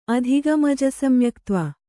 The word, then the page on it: ಅಧಿಗಮಜಸಮ್ಯಕ್ತ್ವ: adhigamajasamyaktva